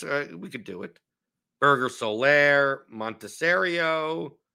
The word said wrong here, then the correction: Monasterio: Monasterio, that's how you pronounce it.